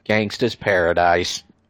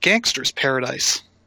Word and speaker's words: Gangsta's Paradise: And he said Gangster's Paradise. – Lost. – They accepted it, but the judges came back and ruled that he mispronounced that.